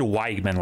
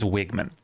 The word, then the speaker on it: Weigman: I think I said Weigman last week, but I think it's Wigman. Connor Wigman.